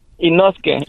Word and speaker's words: Inosuke: ¿Cómo, te, cómo se dice tu, tu apodo? ¿Inoski? – Inosuke, Inosuke.